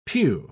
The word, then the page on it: piu: British English